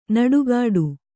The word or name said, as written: ನುಡಿಗೊಡು